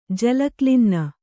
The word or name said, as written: ಜಲಕ್ಲಿನ್ನ